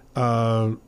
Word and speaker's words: Leicester: Maybe the most shocking one season long was when Leicester, is that how you say it? I think that's how you say it. – Oh, yeah. That Leicester City won the Premier League after being, I guess, fighting off to keep from being relegated for years.